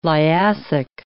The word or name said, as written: Liassic